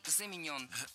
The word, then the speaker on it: заменен: Вторая ошибка – заменен. Это краткое причастие, и ударение в нем такое же, что и в полном. Замененный – заменен.